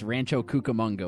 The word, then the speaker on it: Cucamonga: Dan, I don't want to pick on you, but you flubbed the pronunciation. It's Rancho Cucamonga, which I know is in California. – Ends with an A.